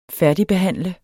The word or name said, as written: færdigbehandle